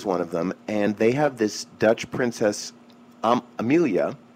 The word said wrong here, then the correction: Amalia: I hope I'm saying it right. No, I think it's Amalia. Amalia.